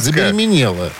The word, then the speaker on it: забеременела: Забеременела. Забеременела. Ставь правильное ударение. Забеременела.